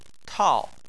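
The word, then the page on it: tao: tone 4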